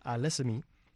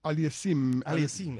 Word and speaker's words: Aliassime: España ya se había puesto en ventaja más temprano cuando Roberto Bautista Agut derrotó a Félix Auger Aliassime. No, espérate. – Aliassime, exactamente.